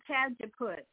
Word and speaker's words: Cajeput: Oh, cat, you mean Cajeput, C-A-J-E-P-U-T, I believe, yeah, Cajeput.